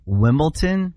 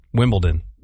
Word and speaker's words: Wimbledon: Yeah, I got D-O-N. Wimbledon? – It's D-O-N. – Like Downton Abbey. – Okay, hold on. Say Wimbledon. It correctly again. – Wimbledon.